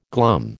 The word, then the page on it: glum: American English